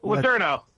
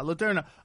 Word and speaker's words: Letourneau: Next up, Joanna Champion versus how do you say her last name? – Letourneau. – Yeah, Letourneau.